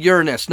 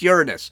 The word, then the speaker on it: Uranus: Yes, I said Uranus, not your anus. That's not how you pronounce it. It's Uranus.